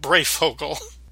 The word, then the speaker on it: Breyfogle: I said his name wrong. Norm frickin' Breyfogle.